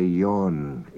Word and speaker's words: Ewan: And you notice we don't say Ewan in Manx, we say Ewan. E-W-A-N is pronounced Ewan.